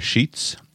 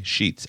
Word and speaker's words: Sheats: Reach out to me on Twitter at Joshua Sheets. Remember, my name is spelled S-H-E-A-T-S. So my Twitter is username is at Joshua, J-O-S-H-U-A, Sheets, S-H-E-A-T-S.